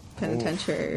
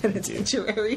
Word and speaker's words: penitentiary: Carlos is writing to some ladies in the penitentiary. – Oof, right now. No, I'm all right. – Penitentiary is how you say it. – Penitentiary. You added one too many Aries.